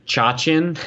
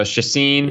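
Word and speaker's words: Chacín: Chacin. There you go. Chacin.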